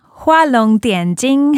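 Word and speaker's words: Hua Long Dian Jing (画龙点睛): It's Hua Long Dian Jing is how you say it in Chinese.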